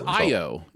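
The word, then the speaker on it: Ayo: It's Io. Yeah, it's Io.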